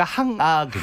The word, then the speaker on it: 항아: 그러면 황하가 아니라 항아. – 항아죠. 항아. 근데 이제 발음상 예, 예, 예. 이제 그렇게 황하로 발음을 했는데 월궁에 있는 항아.